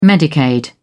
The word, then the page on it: medicaid: British English